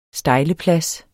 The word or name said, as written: stejleplads